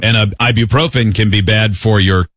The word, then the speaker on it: ibuprofen: Ibuprofen.